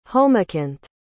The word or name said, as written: homacanth